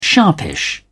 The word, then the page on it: sharpish: British English